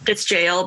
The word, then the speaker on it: gaol: Call it Geisha in Gaol, but it's jail, but they spell it Gaol.